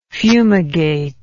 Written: fumigatory

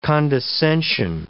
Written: condescension

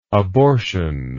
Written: abortional

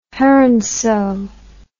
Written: heronsew

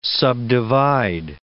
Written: subdivide

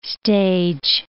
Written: stage

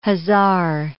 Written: hussar

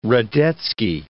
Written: Radetzky

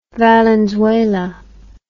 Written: Valenzuela

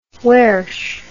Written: wairsh

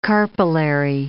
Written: carpellary

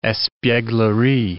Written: espieglerie

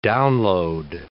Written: download

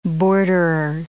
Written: borderer

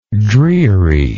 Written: drearisome

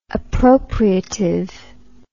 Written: appropriativeness

